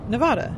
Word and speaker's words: Nevada: Saying it wrong unless you say it in a Spanish accent of some sort. – Right. Which would be Nevada.